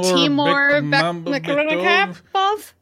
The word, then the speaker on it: Timur Bekmambetov: Timur, Timur Bekmambetov.